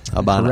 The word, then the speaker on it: Habana: I really like the way you pronounced Habana. – Habana. – Correctly there. – And they'll chew you up and spit you out if you go there saying Havana, Cuba.